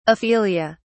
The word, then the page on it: aphelia: British English